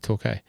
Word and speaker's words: Torquay: It's Torque? No, because they love calling it Torquay. – They pronounce it Torquay.